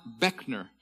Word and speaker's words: Buechner: He says this, B-U-E-C-H-N-E-R. It is my name. It is pronounced Beckner.